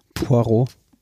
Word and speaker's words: Poirot: P P P Perot, wie heißt der, wie spricht man den aus von Agatha den Heiligen? Poirot.